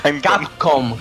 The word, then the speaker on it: Capcom: Camcop. – Espera, repite, Chose, repite. – ¿Cómo? Ya empezamos con las coñitas. – Chose, repite. – Canco. – Bueno. – Capcom, gilipollas. – Ahora sí.